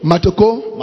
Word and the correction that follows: Matokeo: Matokeo. – Matokeo. – Keo.